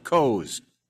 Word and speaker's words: Koz: It's Kaz. K-O-Z. It's pronounced Kaz.